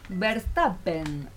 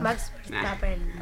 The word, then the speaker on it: Verstappen: Por ejemplo, ¿quién opina de la carrera de Verstappen? ¿Lo dije bien? – Sí, o más o menos. – Max Verstappen.